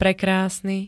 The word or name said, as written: prekrásny